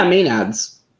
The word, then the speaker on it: Maenads: And then the answer, I don't even know how to pronounce this. Maenads? – Oh yeah, Maenads.